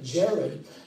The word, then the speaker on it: Jared: And then Mahalalel's son, Yared, as it's pronounced in the Hebrew, but Jared, as we see, means decline.